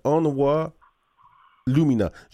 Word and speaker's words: Onwa Lumina: Donc, euh, vous êtes là aujourd'hui pour nous parler de Onwa Lumina. J'espère que je le prononce comme il faut. C'est parfait. C'est parfait.